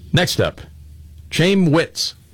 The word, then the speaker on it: Chaim: It's pronounced Chaim, though, I think.